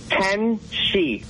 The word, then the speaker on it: tenshi: I call my fiance Tenchi, which is Japanese for angel. – Oh, wow. – How do you say it again? – Tenshi. – Tenshi? – So like the number 10 and she, kind of? – Yes.